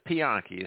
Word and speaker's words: Pianki: My name, ma'am, is Pianki. It's spelled P-I-A-N-K-I.